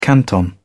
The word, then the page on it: canton: British English